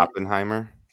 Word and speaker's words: Oppenheimer: Is that how you pronounce it? – Oppenheimer. – Oppenheimer.